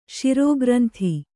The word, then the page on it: ಶಿರೋಗ್ರಂಥಿ: Kannada